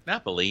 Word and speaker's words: Napoli: Is it Napoli? – Napoli, yeah. – Yeah, Napoli. Okay. So or Naples. – Oh, so, I call it Napoli because in El Paso we have an Italian restaurant called Bella Napoli. – I think in Italian it's Napoli, but for us Americans it's Naples.